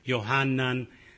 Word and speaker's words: Yohanan: And of course, the J represents the Y sound in Hebrew. So it would be pronounced Yohanan, Yonah, Judah, Yeshua, Isaiah, Eliyah.